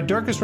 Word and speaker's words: Durkis: Sarah Durkis, Rhymes with Circus,